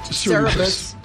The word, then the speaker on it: Cerebras: And Cerebras Systems, or Cerebras, how do you say it? Cerebus or Cerebras? I can't find out. – I think the jury's still out, but Cerebras.